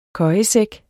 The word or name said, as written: køjesæk